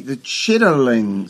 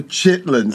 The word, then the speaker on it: chitterling: She talks about the chitterling circuit. You know, what we call the chitlin circuit. Yeah. The chitterling. She pronounces it properly.